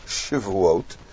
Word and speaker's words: Shivuot: There's a play on words, Shavuot, pronounced a little differently, Shivuot, with a shva under the shin instead of a komatz, is the plural not of Shavua, weeks, but of Shivua, which means an oath.